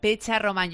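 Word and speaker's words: Petxarroman: Es Alex Pecharromán. Se escribe TX, pero se pronuncia Pecharromán.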